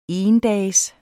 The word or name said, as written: endags